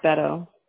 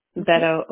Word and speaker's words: Beto: His name's Beto. – Beto? – Beto O'Rourke is how you pronounce that.